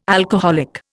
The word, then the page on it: alcoholic: Indian